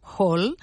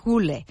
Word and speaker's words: Hole: Si vosaltres, com jo, sempre l'heu anomenat Hole, sapigueu que en noruec i en danès es pronuncia Hule, i la sèrie, que és danesa, es titula Detective Hule.